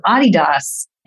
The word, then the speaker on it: Adidas: but the brand is really the nickname of the founder, who is Adi, Adi Dassler. And so Adidas is how to pronounce it if you want to pay homage back to the original pioneer and founder story and entrepreneur behind the brand.